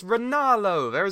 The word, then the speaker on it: Ranallo: Ranallo, not Ranallo. – It's Ranallo.